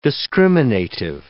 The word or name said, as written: discriminative